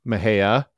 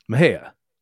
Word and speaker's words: Mexia: Yes, it is pronounced Mejia, named after Jose Antonio Mejia, a Mexican hero for the Republic of Texas Army during the Texas Revolution. – And for our listeners, the it's spelled M-E-X-I-A, but it's pronounced Mejia.